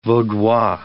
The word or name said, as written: Vaudois